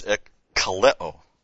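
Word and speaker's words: Ekkaleo (ἐκκαλέω): ekkaleo. So you got EK, then K-A-L-E, long O. Ekkaleo. Meaning, of course, this means to call, to summon, and ek, out from.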